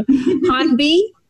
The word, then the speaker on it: Hahnbee: Hahnbee, by saying it right? – Yeah.